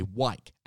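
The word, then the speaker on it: whike: And there's also what are called a wike. It's so hard to pronounce, but it's W-H-I-K-E, a wike.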